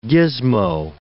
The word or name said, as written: gizmo